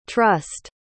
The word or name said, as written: trussed